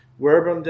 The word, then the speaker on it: verbum: If you're not familiar much with Latin, these are spelled with V's, so it looks like verbum, but in Latin you pronounce a V kind of like a W sound.